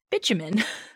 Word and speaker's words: bitumen: Throughout history, there's a black tar-like substance called, and before I say it, you guys, I know what this sounds like, but I looked up the pronunciation, and it is pronounced bitumen.